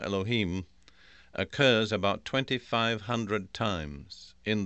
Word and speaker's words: Elohim: We'd probably spell that in English E-L-O-H-I-M, Elohim.